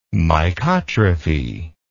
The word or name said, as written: mycotrophy